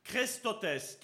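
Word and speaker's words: chrestotes: Et la recherche que j'ai, que faite, c'est que ce mot bénignité est tiré de, du grec qui se dit Christotes, qui ça s'écrit C-H-R-E-S-T-O-T-E-S, e et qui vient lui aussi dire le mot bonté.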